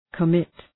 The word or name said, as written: commit